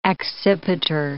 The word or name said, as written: accipiter